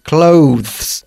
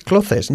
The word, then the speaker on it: clothes: ¿Cómo se pronuncia ropa? Clothes. Tú, al igual que todos los españoles, prácticamente dices clothes. No, it's clothes.